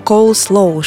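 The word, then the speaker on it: coleslaw: Он появился в Древнем Риме и носит голландское название, которое читается как «коулслоу», что в переводе с голландского означает «капустный салат».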